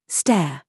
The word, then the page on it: SAUCE: British English